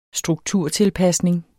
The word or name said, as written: strukturtilpasning